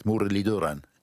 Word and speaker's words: Muralitharan: It is Mudaliduran. – Yes, the TH is a D, which is a dental T with, I think, your tongue touching your teeth, Mudaliduran. Did I sound roughly right? – That's right.